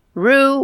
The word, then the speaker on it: Roux: How do you say it again? R-O-U-X? – I would pronounce it as a who, but Roux. Okay. He's considered the founder of the field of immunology and is a co-founder of the Pasteur Institute.